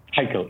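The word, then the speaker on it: heikhal: And that is pronounced Heichel? – Heichel, yes, correct.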